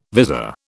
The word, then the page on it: vizor: British English